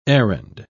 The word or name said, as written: errand